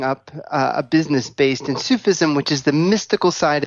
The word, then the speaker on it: Sufism: Sufism? – Sufism.